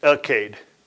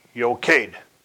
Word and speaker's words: Eochaidh: Some pronounce it Elkade. I'll spell it for you. Eochaidh. Pronounce Jochade.